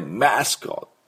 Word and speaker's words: mascot: でこれでマスコットマスコットっていう風に発音します。